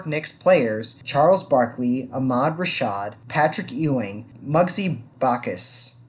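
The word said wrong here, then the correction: Muggsy Bogues: Muggsy Bugs, maybe? – Bugs, yeah. And Sean Bradley.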